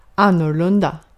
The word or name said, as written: annorlunda